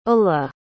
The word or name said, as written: ulla